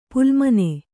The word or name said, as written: ಪಾಲಮನಿ